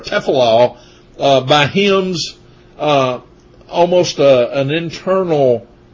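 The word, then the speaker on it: tefillah (תְּפִלָּה): Here in verse 3, the word prayer is Strong's number 08605, and it's pronounced tefillah.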